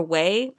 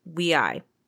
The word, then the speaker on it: WEi: I'm so thankful for Wei. Or sorry, I said it wrong. Wei. That's how you say it.